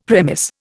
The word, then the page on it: premise: Indian